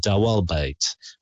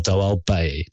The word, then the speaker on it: Dawelbait: That's his first name, and his last name is DAWELBAIT, Dawalbait or Dawalbay, because a lot of those countries speak French where they don't pronounce the T's at the end.